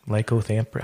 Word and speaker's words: lycanthropy: Lycanthropy. How do you pronounce it? Is that lycanthropy? – Lycanthropy, yeah.